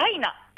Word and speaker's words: DYNA: はい、本日はですね、スポーツアミューズメント& スポーツバーダイナからお届けします。こちらダイナはアルファベット DYNA と書いてダイナと読みます。